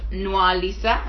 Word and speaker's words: Nwalisa: Joining me on this episode of Thinkers Tea is a very special lady, Mrs. Hope Nwalisa.